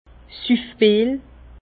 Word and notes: Süffbil: Bas Rhin; Herrlisheim

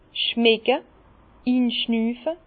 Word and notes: schmecke, inschnüfe: Bas-Rhin; Herrlisheim

